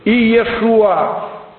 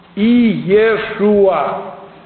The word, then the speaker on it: Иешуа: Если брать еврейский язык, а правильный арамейский язык, на котором разговаривали во дни Иисуса Христа, это имя произносилось «Иешуа». «Иешуа».